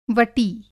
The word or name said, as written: वटी